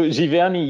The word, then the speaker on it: Giverny: So Giverny,